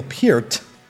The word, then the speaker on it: Peart: He still pronounced it Peart, but of course, to hear Neil Peart, reading the audiobook, and he's actually pretty good at reading it. He obviously must have pronounced his own name right, so now I know Rush's drummer, the best drummer in the world, is Neil Peart.